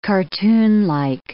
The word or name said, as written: cartoonlike